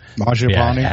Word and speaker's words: Mangiapane: Has Mangiapane, sorry if I'm butchering that name, Mangiapane,